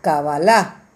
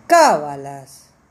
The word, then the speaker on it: Cabalá: Recuerda, Cabalá con K y acento en la última A, Cabalá, y no Cábalas.